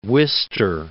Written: Wister

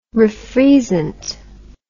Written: refreezant

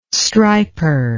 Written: striper

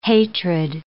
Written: hatred

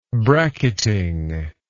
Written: bracketing

